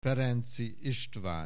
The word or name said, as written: Ferenczy Istvan